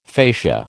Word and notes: facia: American English

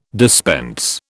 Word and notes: dispense: British English

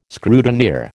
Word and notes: scrutineer: American English

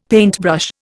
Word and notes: paintbrush: Indian

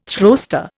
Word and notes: throwster: Indian

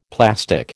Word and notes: plastic: American English

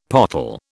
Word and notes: pottle: British English

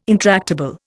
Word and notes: intractable: Indian

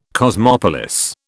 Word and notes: cosmopolis: British English

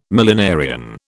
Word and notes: millenarian: British English

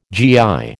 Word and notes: GI: American English

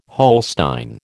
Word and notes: Holstein: American English

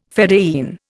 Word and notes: fedayeen: Indian

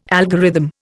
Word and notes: algorithm: Indian

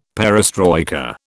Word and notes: perestroika: British English

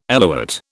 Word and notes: eluate: British English

